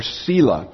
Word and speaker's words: Selah: It's used three times in this psalm, and it's the word Selah, or Selah. Or if I pronounced it in the Hebrew, it would be misunderstandable. Let's just say Selah.